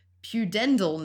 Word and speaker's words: pudendal: Pudendal nerve. That sounds right. I think pudendal. that's right.